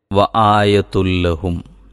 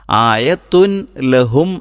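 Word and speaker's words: വ ആയതുല്ലഹും (آيَةٌ لَهُمُ): വ ആയതുല്ലഹും, അങ്ങനെയാണ് ഓതേണ്ടത്. വ ആയതുല്ലഹും, എന്താണിവിടെ സംഭവിച്ചത്? ആയത്തുൻ എന്നതിലെ ആ നൂന്, ഉച്ചാരണത്തിൽ വരുന്ന നൂന് ലാമിൽ അലിഞ്ഞു ചേർന്നാണ് വായന നടക്കുന്നത്, പാരായണം നടക്കുന്നത്. ആയത്തുൻ ലഹും എന്നല്ല, മറിച്ച് വ ആയതുല്ലഹും ഒന്ന് പറഞ്ഞു നോക്കിയാൽ നമുക്ക് പിന്നീട് എല്ലാ ഭാഗത്തും ശ്രദ്ധിക്കാൻ പറ്റും.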